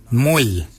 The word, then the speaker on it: mooly: Warrigal is spelled W-O-R-R-A-G-O-L. Warrigal. Warrigal is your upper mouth. For your nose, we say mull. Mull is your nose. Mull is spelled M-O-O-L-Y.